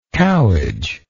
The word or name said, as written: cowage